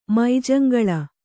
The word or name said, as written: ಮೈಜಂಗಳ